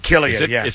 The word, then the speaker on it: Cillian: Killian, Is it a hard K? – It's a C, but it's a K pronounced, yeah.